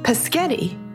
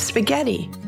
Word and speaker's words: spaghetti: You know, I used to say paschetti instead of spaghetti when I was younger.